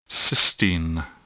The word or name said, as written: cystine